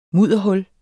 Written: mudderhul

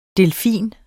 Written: delfin

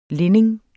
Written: linning

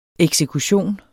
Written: eksekution

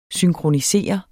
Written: synkronisere

